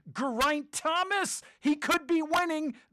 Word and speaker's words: Geraint: I've been pronouncing his name Geraint, like he had some French blood, Geraint, but I've heard that he's Welsh. He's Welsh, and he says, it's Geraint, Sonny.